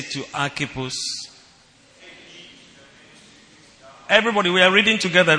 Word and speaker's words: Archippus: Archippus.